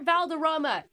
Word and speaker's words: Valderrama: Wilmer Valderrama. – It was just a pronunciation issue. Valderrama.